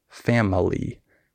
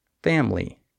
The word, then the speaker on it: family: Also, with skipped syllables, for example, in the word family, the I is silent. We don't say family. We say family.